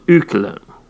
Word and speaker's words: Öcalan: it's an O with umlaut, Öcalan.